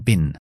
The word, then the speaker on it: been: Entonces, contraemos have, I've, ahora lo veremos, lo voy a dejar como una F, I've, Bean lo dejo en bin.